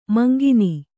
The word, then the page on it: ಮುಂಕಣ್ಣು: kannada